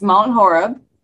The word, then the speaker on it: Mount Horeb: If you'll remember, Skull Public House is in a small community, I believe Mount Hebron. Am I pronouncing that right? – No, you're totally not pronouncing that. – I'm still learning. – It's Mount Horeb.